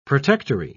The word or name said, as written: protectory